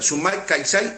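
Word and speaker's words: Sumak Kawsai: Te lo deletreo, S-U-M-A-K, A-K. – Sí, Sumac. – Sumac, K de kilo, A-W-S-A-I, Caixay. Así se pronuncia. Sumac Caixay, en lengua cacán, quiere decir buen vivir.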